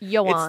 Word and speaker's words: Ioan: Well, it's spelled I-O-A-N. – Which I thought would be A-N or something. – And then G-R-U-F-F-E-D. – Griffith. It's actually Griffin. – Yes. – It's Welsh. Johan.